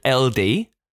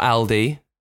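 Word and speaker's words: Eldey: The last place they lived was in a place called Eldi. Not Aldi. Great Middle Isle, but Elde, I think is how you say it.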